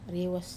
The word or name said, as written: rewas